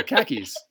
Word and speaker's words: khaki: Okay, so it's, we pronounce it khaki. – Khaki. – Like khaki. – Yeah, because that's... khaki is like shit. Khaki is cack. It's poo.